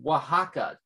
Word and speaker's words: Oaxaca: Becca Cardenas in the comments feed saying it's pronounced more like Oaxaca, W-A-H-A-C-A, LOL, my mom's from there. Oh, okay, fair enough. All right, so Oaxaca is how that's pronounced.